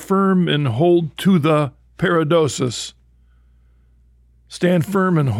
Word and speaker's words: paradosis: But the word tradition in the New Testament comes from a Greek word, and make sure I'm saying this right, Monsignor, paradosis. – Right. – Is that right? – Tradition, paradosis. Uh-huh.